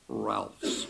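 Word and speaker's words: Rahlfs: Ralph's is spelled R-A-H. LFS, Ralph's.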